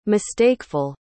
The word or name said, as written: mistakeful